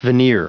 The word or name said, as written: veneer